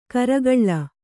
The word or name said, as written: ಗೋರಿಕಲ್